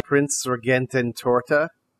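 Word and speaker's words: Prinzregententorte: So I wrote down a phonetic pronunciation for this. The Prince Regentin Torta, I think is right.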